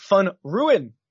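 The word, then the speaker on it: Van Rooyen: And Eric Van Ruin, I believe is how Mark Immelman taught me how to pronounce that. – Yeah. Nobody else pronounces it that way, by the way.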